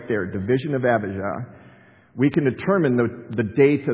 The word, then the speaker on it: Abijah: And it's Abijah is the way we say it, Abijah. They don't pronounce J's in Hebrew.